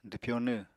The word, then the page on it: dwpyonw: apatani